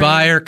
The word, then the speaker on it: Byer: So Byer kind of rhymes with Briars.